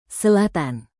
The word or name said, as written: selatan